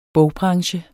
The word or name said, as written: bogbranche